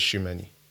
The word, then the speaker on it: Tchouaméni: we're going to see Bellingham versus Tchouaméni.